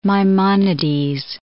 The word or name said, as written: Maimonides